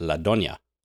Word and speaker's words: La Doña: But this one is called La Donna or La Doña. I don't know. I don't want to offend anyone by my pronunciation, but there is an accent on the N and I think it's La Doña.